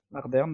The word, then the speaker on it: Ardern: Je sais pas trop comment ça se prononce, excusez-moi. – Ouais, Ardern, d'accord.